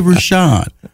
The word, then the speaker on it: Rushion: Is it Rushion or Rushion? And the guy said, pick a name, brother. I remember it was 22. He said, pick a name. I said, Rushion? He said, but don't say it like that. Don't say it like you're guessing. Say Rushion. Okay, Rushion.